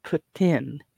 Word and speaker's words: poutine: I think they had the phonetic of it, P-U-T-hyphen-E-E-N, poutine. How they're pronounced.